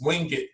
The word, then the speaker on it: Wingate: It looks like Wingate, but it's Wingate. That's how they pronounce it.